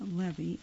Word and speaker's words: Levy: Of course, that could be Levy or Levi. I'm pronouncing it Levy, L-E-V-Y.